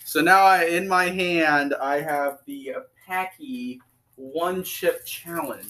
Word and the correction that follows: Paqui: It's pronounced Paqui.